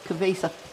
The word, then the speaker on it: Cabeza: You've got, I'm gonna probably mispronounce a bunch of the names, but Esteban the Moor, Marcos de Ninza, Jacques Carter, Cabeza de Vaca. – Cabeza. – See, you know how to pronounce them.